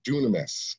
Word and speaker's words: dunamis: The word power there is what's called dunamis. That's the way you actually pronounce it.